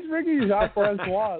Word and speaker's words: Jean Francois: It's Ricky Jean Francois.